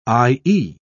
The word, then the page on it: i.e.: British English